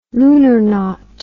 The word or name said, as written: lunarnaut